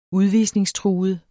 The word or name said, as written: udvisningstruet